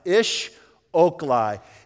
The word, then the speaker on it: Esh oklah: Ish Oklai is the word. It's actually spelled E S H O K L A H, but it's pronounced Ish Oklai.